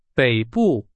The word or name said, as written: 北部